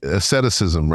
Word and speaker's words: asceticism: It also is not saying that we're aesthetic. Aesthetic. How do I pronounce that? It's too early in the morning again. – I know. – Once again. The asceticism, right? – Yes.